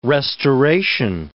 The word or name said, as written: restoration